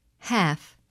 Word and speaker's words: half: The letter L is silent in the following words. Read them and repeat each one during the pause. – Half, halves, salve, could, should, would, Lincoln.